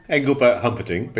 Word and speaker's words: Engelbert Humperdinck: I think my first, my best one was um, not one that I did, but it was one that I really loved, was Engelbert Hunkerdink, if that's how you pronounce his name. – Engelbert Humperdinck, but yes, we, we, we knew who you were talking about, yeah.